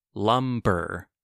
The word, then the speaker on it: lumber: I can think of one right now that doesn't have a silent B. Lumber. B-b-b. Lumber. L-U-M-B-E-R.